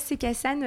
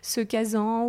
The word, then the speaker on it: Se Casan: Alors déjà, Cécassane, euh, déjà tu le prononces très bien parce que des fois j'entends ce euh, casan, ou euh, enfin on ne sait pas toujours le prononcer.